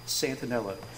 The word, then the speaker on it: Santanella: My name is Andy Santanella, the eye is silent, of the Mecklenburg County Bar and law firm of Pope, Hayward, Sweeney, and Santanella.